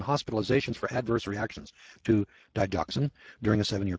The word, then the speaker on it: digoxin: For example, the FDA received an average of 82 reports each year about adverse reactions caused by the drug digoxin, or D-I-G-O-X-I-N, however you pronounce that, digoxin.